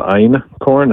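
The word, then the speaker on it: Einkorn: I mean, the major one is called Ein corn. It's spelled E-I-N and then K-O-R-N, like Ein and then corn.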